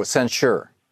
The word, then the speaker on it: censure: a censor, so that a censor. I'm not pronouncing it correctly, uh, so that there's something historically noting what we're going through. – Oh, censure.